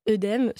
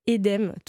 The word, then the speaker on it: œdème: La plupart des mots dans lesquels il y a un « e » dans l'eau, par exemple, suivi d'une consonne, comme par exemple le mot qu'on prononce tous et toutes « œdème », se prononce en réalité « édème »,